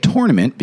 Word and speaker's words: tournament: But I would say the NCAA tournament because that's how it's properly pronounced, because it's got the word tour in it, the rest of the country.